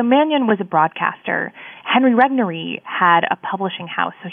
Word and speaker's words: Regnery: Clarence Mannion was one of them, but there were also two others, William Rusher and Henry Regnery. Am I saying that correctly? – That's right. Yes, these are the main characters of the book.